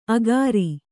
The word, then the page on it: ಅಖಾರಿ: akhāri